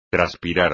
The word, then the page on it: traspirar: espagnol